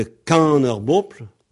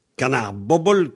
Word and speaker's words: Kan ar Bobl: Tout le monde a entendu parler de canard-bople, que certains prononcent canard-bobble, canard-bople, enfin, il y a des choses bizarres comme ça. Canard-bople, K-A-N-A-R-B-O-B-L.